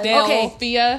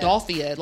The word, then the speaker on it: Philadelphia: Philadelphia. Okay. – Say it. – That's it. Del. – Philadelphia. – You're saying Dolphia, like dolphin.